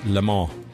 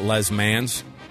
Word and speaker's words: Le Mans: That's how you say it, by the way. It's Le Mans. It's not Les Mans. You'll get in trouble for saying it.